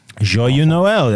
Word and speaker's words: Joyeux Noël: She was in Joyeux Noël. – Oh, my God. – Christmas. – Your pronunciation. – Joyeux Noël.